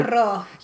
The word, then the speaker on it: Pizarro: In 1521, the gold-hungry Spanish conquistador Francisco Pizarro, that's the double R that I can't Pizarro. – Do. – Pizarro.